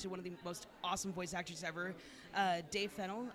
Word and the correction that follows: Fennoy: I believe is how it's pronounced. – Dave Fennoy. – Fennell. Okay, I was close. – Yeah, it's like Annoy, only Fennoy.